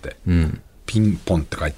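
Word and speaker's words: PING: ピン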